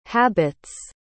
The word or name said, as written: habits